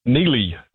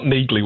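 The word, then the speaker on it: Neagley: It's the first spin-off from that monster action thriller series, and it focuses on his occasional partner, Neely, and it's not Neagly, which I thought it was as well, despite the hard G in her name. Neagly. So there's a bit of a trap. I'm not sure why they did that, but that's her name.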